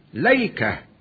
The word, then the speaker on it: ليكة: وورد لفظ الأيكة في هذه السورة وسورة صاد بصيغة ليكة، ليكة على وزن ليلة،